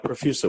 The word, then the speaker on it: Profusa: I have Ben Huang, CEO of Profusa, P-R-O-F-U-S-A. I'm not sure if I pronounced it right, but Ben, is that right? Profusa, the best way to pronounce the company? – You did. That was perfect.